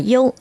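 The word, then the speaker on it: dô (柚): Vì trong tiếng Trung, bưởi được đọc là dô, dô chữ.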